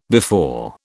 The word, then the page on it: before: British English